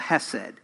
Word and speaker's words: hesed: It's called hesed. We would, in English, spell it H-E-S-E-D.